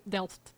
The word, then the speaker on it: Delft: In Delft. – Delft. Mm. Okay. you pronounce it very good for a Bulgarian girl.